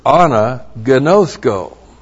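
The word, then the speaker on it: Anagnosko (ἀναγινώσκω): Two, anagnosis comes from the verb anagnosko. Looks like that in the Greek, A-N-A-G-I-N, long O, S-K, long O. Anagnosko.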